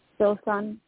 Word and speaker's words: Filsan: My name is Wilson, and I'm calling from Ohio. And I would just like whatever card you feel drawn to from. – Okay. And what did you say your name was? – Filsan, F-I-L-S-A-N.